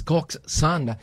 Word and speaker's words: coxswain: So, did you know that Coxswain pronounced as Coxswain, mga kapuwersa?